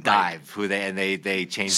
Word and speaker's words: DIIV: It's Dive, D-I-I-V. It was called, you spell it the regular way, but it's after the Nirvana song.